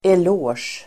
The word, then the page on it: eloge: el'å:sj